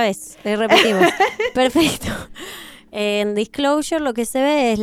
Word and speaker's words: Disclosure: ¿Está bien mi pronunciación? – Disclosure está perfecta.